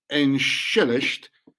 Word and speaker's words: entschuldigt: Entschuldigt, pronounced entschuldigt, meaning sorry, unguilt me, sounding a lot like inshallah.